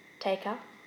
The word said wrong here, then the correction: Taika: Taika Waititi.